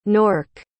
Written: Nork

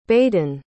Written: baeddan

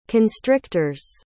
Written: constrictors

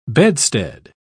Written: bedstead

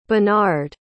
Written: benard

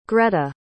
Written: grete

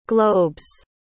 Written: globes

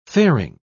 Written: fairing

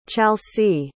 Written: chalsie

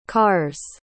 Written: cars

